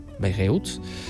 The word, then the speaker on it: Bayreuth: que parece que se dice así, Bayreuth, de Alemania, con la dirección de Pablo Gras Casado.